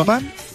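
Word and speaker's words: Oostmalle: ¿Lo he pronunciado bien? – Ostmalt. Perfectamente.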